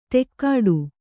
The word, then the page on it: ತೆಕ್ಕಾಡು: tekkāḍu